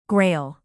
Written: grail

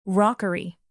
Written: rockery

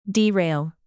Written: derail

